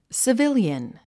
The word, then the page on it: civilian: sivíljən; スィビリアン